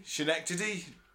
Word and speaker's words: Schenectady: Is that Shady? Oh, man, how do you say that? – Schenectady, New York. – Maybe. – Thank you.